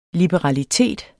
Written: liberalitet

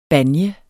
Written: banje

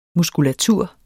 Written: muskulatur

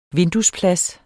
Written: vinduesplads

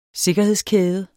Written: sikkerhedskæde

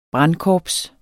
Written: brandkorps

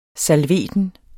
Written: salveten

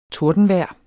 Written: tordenvejr

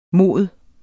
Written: mod

